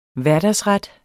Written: hverdagsret

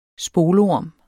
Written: spolorm

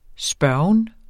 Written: spørgen

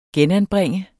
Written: genanbringe

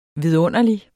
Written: vidunderlig

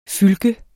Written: fylke

